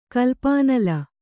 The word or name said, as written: ಕಲ್ಪಾನಲ